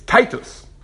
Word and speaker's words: Titus: He says, you pronounced it wrong. It's Titus.